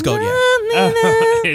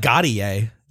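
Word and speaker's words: Gotye: Yeah, it's actually pronounced Goat Yay. – Is it? – Yeah. – It's Goat Yay? – Goat Yay, yeah. – Okay. – Goat Yay. – It's not Gotti Yay. That, whatever you said.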